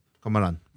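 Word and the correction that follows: Komaram Bheem: Komaram. – Komaram Beam. Komaram Beam.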